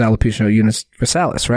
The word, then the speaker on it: alopecia universalis: He has alopecia universalis, right? So did I pronounce that right? – Yeah, you said it right.